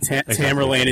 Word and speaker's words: Tamerlane: But Tamerlane is the European way of pronouncing his name.